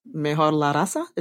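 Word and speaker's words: mejorar la raza: Mejor la raza? Is that how you say it? – Yeah, you got it.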